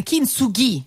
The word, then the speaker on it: Kintsugi: La voce di Elisa su Radio Number One con Kintsugi. Noi mettiamo un bel accento sulla I perché la questione dell'accento tonico nel vocabolario nipponico è sempre molto accesa.